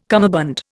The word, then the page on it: cummerbund: Indian